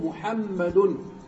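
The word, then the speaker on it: محمدٌ: لكن هذه النون نون ساكنة تتبع آخر الاسم لفظا، باللفظ تنطق، تقول محمد، وتفارقه خطا بالكتابة، لا تكتب نون، لا تكتب نون، يستغنى عنها بماذا؟ بواو صغيرة فوق الواو التي هي الحركة، محمد وفوقها واو صغيرة أخرى يستغنى عن النون هذه.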